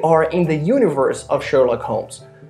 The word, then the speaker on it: Sherlock: Sherlock. So the R is very strong, so Sherlock.